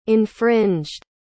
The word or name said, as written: infringed